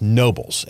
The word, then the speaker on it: Nobiles: As I told you on the show, one of the places you can go eat while you're there is this place, Nobles. It's spelled N-O-B-I-L-E-S.